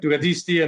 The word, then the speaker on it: Ducatisti: Ducatisti. – Right. – So how, first of all, did I pronounce that right? – Yep. Ducatisti.